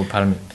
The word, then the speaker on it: palmier: And then this one is palmies. – Palmier. So again, this one has the S at the end, just for plural, but so you don't pronounce it. – Okay. – So palmier.